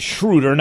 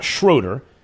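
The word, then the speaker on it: Schröder: And Dennis Schroeder, that's how you pronounce it, Schroeder, not Schroeder.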